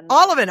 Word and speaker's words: Oliven: Oliven. – Okay. Just the way it's written.